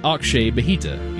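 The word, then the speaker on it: Akshay Bhatia: There's a pro golfer named Akshay Bahita, pronounced Akshay Bahita. He spelled it out for me.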